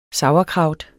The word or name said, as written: sauerkraut